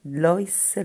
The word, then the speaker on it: Lois: è The Giver di Louis Lorry, Lois Lorry, scusate la pronuncia.